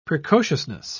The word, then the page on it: precociousness: British English